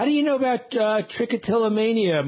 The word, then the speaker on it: trichotillomania: Molly is in Green Bay with what she thinks is the definition of how did you pronounce it? Trichotillomania. – That was great.